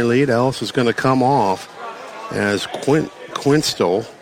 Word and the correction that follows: Questel: Am I saying that right? – Chandler Questel.